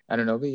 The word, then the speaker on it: Anunoby: Anunoby? Yeah, you said it right.